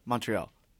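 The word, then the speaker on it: Montréal: How do you say it? – Montreal. – That's correct.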